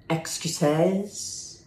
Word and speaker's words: excuses: It's actually pronounced excuses. The emphasis is on the second E.